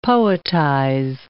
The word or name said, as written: poetize